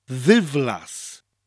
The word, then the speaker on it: ddiflas: Once it soft mutates, it becomes divlas, with a TH sound at the beginning of the word, a th sound, divlas.